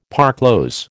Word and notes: parclose: American English

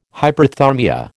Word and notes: hyperthermia: American English

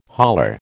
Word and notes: holler: American English

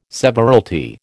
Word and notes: severalty: American English

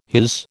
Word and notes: his: American English

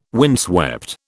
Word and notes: windswept: British English

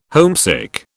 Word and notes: homesick: British English

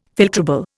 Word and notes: filtrable: Indian

